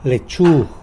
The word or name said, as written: lechu